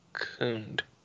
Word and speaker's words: cooned: You know, he says basically like saying cook, cool. for the oo sound, cook, cooned.